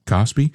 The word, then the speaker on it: KOSPI: The benchmark KOSPI, how do you say this? – KOSPI. – The KOSPI index more than tripled in value, driven by the faith in the AI boom.